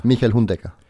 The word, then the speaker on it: Michael Hundegger: It's Michael. – Michael. – Michael Hundegger. – Michael Hundegger. – Yeah.